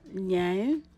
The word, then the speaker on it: Nyei: My name is Zainab Nye. – Can you please spell it, your first name and your last name for me? – And my first name is Zainab. Last name, Nyei.